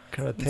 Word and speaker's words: karate: That's the Americanized version. – Yeah. – For sure. – In Japanese, T-E is pronounced te. – Karate. – So it's karate.